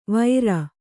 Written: ವೈರ

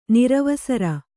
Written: ನಿರವಸರ

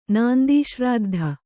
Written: ನಾಂದೀಶ್ರಾದ್ಧ

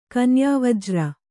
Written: ಕನ್ಯಾವಜ್ರ

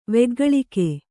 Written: ವೆಗ್ಗಳಿಕೆ

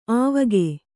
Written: ಆವಕ್

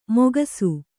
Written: ಮೊಗಸು

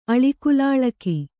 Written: ಅಳಿಕುಲಾಳಕಿ

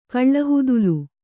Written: ಕಳ್ಳಹುದಲು

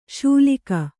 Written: ಶೂಲಿಕ

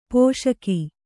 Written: ಪೋಷಕ

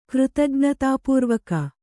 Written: ಕೃತಜ್ಞತಾಪೂರ್ವಕ

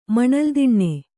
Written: ಮಣಲ್ದಿಣ್ಣೆ

